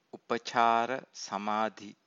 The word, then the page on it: upacara samadhi: Pali